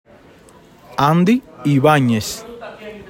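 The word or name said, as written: Andy Ibáñez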